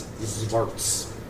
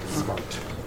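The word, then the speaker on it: Xvart: So knowing now that, having identified it as a symbol of Raxivort, you identify these creatures as Zvarts. It's X-V-A-R-T. Zvart.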